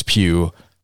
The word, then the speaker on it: Pugh: Florence Pugh.